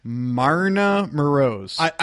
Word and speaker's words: Marina Moroz: We've wrapped up Fastest Fight News, but I can't let us move on in the show until I pronounce her name right. Marina Moroz. – I believe that's correct.